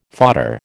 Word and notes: fodder: American English

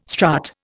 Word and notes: strut: Indian

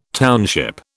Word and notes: township: British English

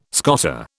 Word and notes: scoter: British English

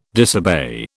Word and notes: disobey: British English

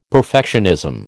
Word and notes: perfectionism: American English

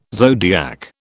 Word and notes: zodiac: British English

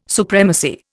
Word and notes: supremacy: Indian

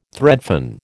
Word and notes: threadfin: American English

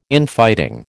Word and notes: Infighting: American English

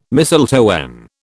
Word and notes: mistletoe: British English

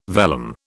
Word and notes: vellum: British English

